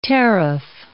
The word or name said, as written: teraph